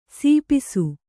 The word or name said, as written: ಸಾಪಿಸು